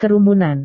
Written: kerumunan

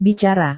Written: bicara

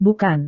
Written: bukan

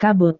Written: kabut

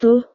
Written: tuh

